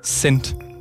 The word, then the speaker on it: Xennt: og ham her, Herman Johans øh, Sendt, tror jeg det skal udtales, X-E-N-N-T,